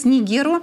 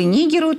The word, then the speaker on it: Нигеру: А Мали и Буркина-Фасо помогают сейчас Нигеру или Нигеру, тут два ударения, возможно, готовятся к отражению интервенции Франции и Нигерии под флагом ЕКОВАС.